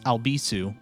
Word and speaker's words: Albisu: You can find me at Alex Albisu. Last name is spelled A-L, B as in boy, I, S as in Sam, U.